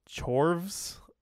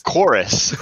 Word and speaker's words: Chorus: Next was Chorvs. I think that's how you pronounce that. – It's Chorus.